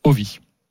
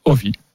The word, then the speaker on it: Ovi: Non, c'est Ovi. Ah bon. Voilà, Ovi. C'est comme ça que l'on prononce.